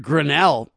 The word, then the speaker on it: Grenell: Richard Grenell.